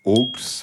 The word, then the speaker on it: aulx: On dit que parfois, au pluriel, en effet, ce mot se dit aux et s'écrit A-U-L-X.